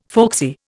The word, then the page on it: folksy: Indian